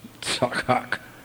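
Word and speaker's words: tzakak (צחק): The Hebrew verb to laugh is pronounced tzakak.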